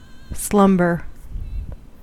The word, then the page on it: slumber: American English